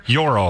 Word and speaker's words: Joro: The J-O-R-O. Euro spider? – Euro, I think, is how you say it.